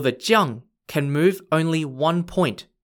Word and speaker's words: Jiang: This piece is called the Shuai if you're on the red side, or Jiang if you're on the black side. Shuai is spelt S-H-U-A-I, and Jiang is spelt J-I-A-N-G.